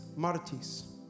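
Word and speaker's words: martis (μάρτυς): The word witness actually is martis. So turn to the person next to you and say, you are a martis. You got to roll your R a little bit.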